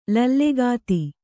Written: ಲಲ್ಲೆಗಾತಿ

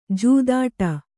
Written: ಜೂದಾಡಿ

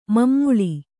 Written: ಮಮ್ಮುಳಿ

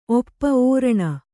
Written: ಓಪು

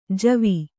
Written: ಜವ

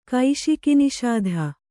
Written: ಕೈಶಿಕಿನಿಷಾಧ

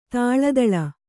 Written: ಥಳಥಳ

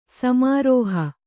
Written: ಸಮಾರೋಹ

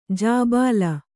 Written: ಜಿಬಿಲಿ

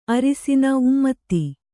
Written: ಉಮ್ಮದ

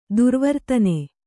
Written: ದುರ್ವರ್ತನೆ

